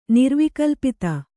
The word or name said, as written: ನಿರ್ವಿಕಲ್ಪಿತ